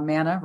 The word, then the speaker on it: Manna: Uh, mana rhymes with Havana, and um, it's about really shifting.